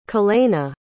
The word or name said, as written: kalenna